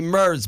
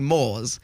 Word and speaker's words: Murs: when I heard Snoop say my name for the first time, it was like, Ollie Murs, big Snoop Dogg. I was like, oh my God. But when Shaggy does Ollie Moores, he calls me Ollie Moores.